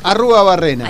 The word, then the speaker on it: Arruabarrena: Arruabarrena. ¿Cómo se dice? – Rodolfo Arruabarrena. – Ahí está. – Barrenando con Arruabarrena.